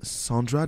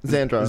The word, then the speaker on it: Zandra: I wish, is her name, girls, is her name Sandra? There's like, is it? Sandra. Oh, what's her name? Can you say so I don't butcher it? – I think it's Zandra White. Is it White? – Zandra.